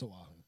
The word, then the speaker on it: Oahu: So, this guy, Mikey Wright, was enjoying a beer and filming the waves on, I'm going to say this wrong because I always get Hawaiian names wrong, Oahu? – Oahu? Oahu. Oahu. Yeah, it's Oahu. – You get I guess you get Oahu.